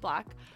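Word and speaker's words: 6LACK: It's spelled six, L-A-C-K, but pronounced Black.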